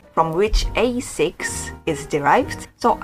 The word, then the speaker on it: ASICS: Ooh, so I'd pronounce this as Asics. Asics. Americans, I think, would say Asics, but I'd say Asics.